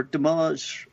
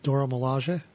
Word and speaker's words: Dora Milaje: Well, since Josh was talking about T'Challa and T'Chaka, I'm going to talk about the Dora Milaje, or Damage, first appeared in the Black Panther. – Wait. Let's get a handle on how that's pronounced. – Dora Milaje? – Dora Milaje.